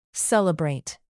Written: celebrate

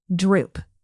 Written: drupe

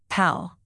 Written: pal

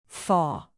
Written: thaw

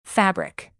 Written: fabric

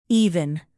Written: even

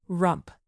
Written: rump

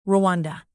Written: rwanda

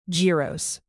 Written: gyros

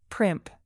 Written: primp

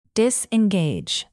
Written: disengage

